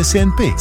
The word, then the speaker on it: CNP: Si legge Senpe, si scrive CNP.